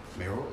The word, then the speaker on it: mayoral: the mayoral election, that's how you say it. I've been like really illiterate the last couple weeks. – Mayoral? – Is that it? – Mayoral, yeah.